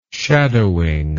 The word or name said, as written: shadowing